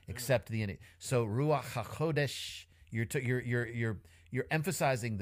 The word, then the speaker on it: Ruach HaKodesh: A more truer pronunciation of it is Ruach HaKodesh.